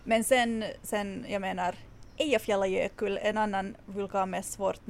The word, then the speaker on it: Eyjafjallajökull: Ja, som de facto om jag nu får vara besserwisser så ska det visst uttalas Eyjafjallajökull.